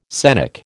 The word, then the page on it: scenic: American English